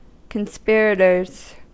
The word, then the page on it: conspirators: American English